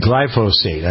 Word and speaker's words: glyphosate: It's glyphosate, I think.